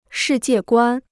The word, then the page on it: 世界观: shì jiè guān